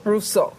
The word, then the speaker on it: Rousseau: Rousseau.